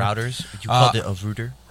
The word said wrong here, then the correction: Reuters: Reuters. – Well, I mean, R-E-U-T-E-R-S. Isn't that Reuters? Routers is an O.